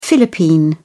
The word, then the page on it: philippine: British English